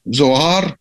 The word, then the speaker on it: Зоар: Йоханан бен Закай, он был его учеником, этого раби Акивы, и он написал знаменитую книгу Зоар, если правильно говорить,